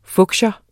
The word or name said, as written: fuchsia